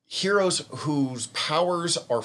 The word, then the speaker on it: Hxeros: It's called Super, and it's kind of still pronounced Heroes, but it's spelled H-X-E-R-O-S. So it's Heroes and, like, Eros and, like, Zer, I don't know.